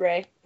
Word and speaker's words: Ra: I just looked up how to pronounce it, but they named themselves after the Egyptian sun god, and I did not know that you pronounce it Ray. – I thought it was Ra. – I did too, but no, it's Ray.